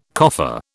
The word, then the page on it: coffer: British English